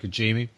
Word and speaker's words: Kijimi: Kijimi.